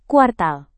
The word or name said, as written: kuartal